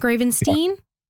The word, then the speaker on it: Gravenstein: Then we have Walter and NDG, you're going to have to correct my pronunciation of this. Gravenstein? – Yeah. Gravenstein? That's right.